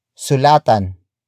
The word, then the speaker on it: sulatan: Word for word it's sulatan ko kaya si Bobby.